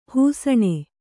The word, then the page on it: ಹಿಸುಣ: Kannada